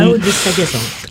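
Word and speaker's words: Zaho de Sagazan: Zao de Sagazan.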